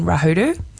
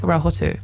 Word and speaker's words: Rahotu: I think this is really awesome because I thought my whole life that I grew up in Rahuru. It turns out that that's not how you say it. You say it something like Rahotu.